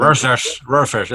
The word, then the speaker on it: Rorschach: ya como tal está establecido Rorsch, ah, siempre se, no pronuncio. – Rorschach, es que es raro ese nombre.